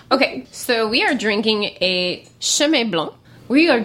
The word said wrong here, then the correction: Chenin Blanc: drinking a Chenin Blanc 2014.